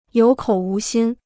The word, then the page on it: 有口无心: yǒu kǒu wú xīn